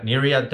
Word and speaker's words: Nereid: Michael Kaufman is the CEO of Nereid Therapeutics. Did I pronounce that correctly? – Beautifully.